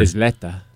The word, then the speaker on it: Isleta: Eslita? Is that how you say it? Eslita? That's how I heard. – Isleta.